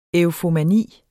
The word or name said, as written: EUFOMANI